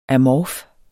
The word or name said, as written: amorf